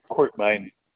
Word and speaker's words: Kortbein: Kortbein. – Could you spell it? – Okay. K-O-R-T-B-E-I-N. – E-I-N? – Yeah.